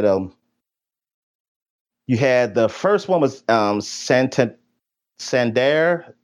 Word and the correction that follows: Santander: Oh, Santander. I forgot how you pronounce his name. Um, Anthony Santander.